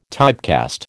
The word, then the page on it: typecast: American English